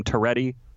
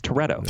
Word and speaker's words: Toretto: So I have just one comment in that, but neither of you seem to know how to pronounce Dom Toretto's name. JVL, you called him Toretti, and Vic, you called him like Toretto. – Toretto. – Like he's a godfather. – Yes, well, he is Dom. Dom is. What are you saying? – You're both idiots. – Well, besides that, would you pronounce it differently? – Dom Toretto. It's Toretto.